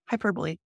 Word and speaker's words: hyperbole: But they spelled hyper and then B-O-L-E. – Yep, hyperbole. – Wow, I'm just dumb. Okay. – It's a weird word. It doesn't seem like it should be pronounced that way.